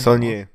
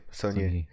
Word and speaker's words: Saulnier: Von Jeremy Saulnier, spricht man den Saulnier. Saulnier.